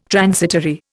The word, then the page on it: transitory: Indian